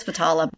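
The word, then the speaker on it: Vetala: Stories of the Vitaha. No. – I must just say I'm enjoying the struggle with pronunciations. – I know, because it's usually you. – It's usually me on the other side. – It looks like it's Vitala. So,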